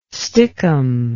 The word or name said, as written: stickum